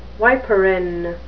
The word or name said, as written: viperine